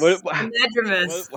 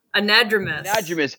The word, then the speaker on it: anadromous: fish, not androgynous. What's the word? I always confuse androgynous. – Anadromous. – How do you say it? – Anadromous. – I always want to say androgynous.